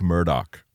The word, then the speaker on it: Murdaugh: It's spelled Alex Murdaugh, but then it's pronounced Alec Murdoch.